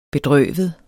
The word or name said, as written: bedrøvet